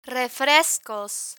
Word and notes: refrescos: Spanish

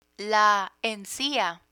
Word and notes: encia: Spanish

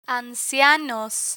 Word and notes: ancianos: spanish

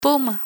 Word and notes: puma: Spanish

